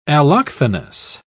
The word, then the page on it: allochthonous: British English